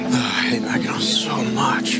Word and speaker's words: Mackinac: When he goes to Mackinac Island? – Oh, no, Mackinac Island. – Mackinac Island. – First of all, yeah. – It's spelled, why do they spell it Mackinac when it's Mackinac?